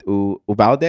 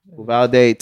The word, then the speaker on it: Uvalde: It was a mass shooting at Uvalde. I think that's how you pronounce it. – Yeah. – Uvalde, Texas.